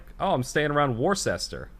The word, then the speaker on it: Worcester: But I'm thinking of like the town I grew up around, and where I went to college, was, we say Worcester. It's spelled WOC. – RCESTER. – So it's, I honestly forgot the R was even in it.